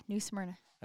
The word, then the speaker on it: New Smyrna: Yeah, New Smyrna.